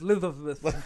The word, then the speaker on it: Elizabeth: And, it's pronounced Elizabeth.